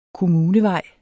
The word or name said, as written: kommunevej